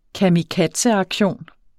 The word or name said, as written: kamikazeaktion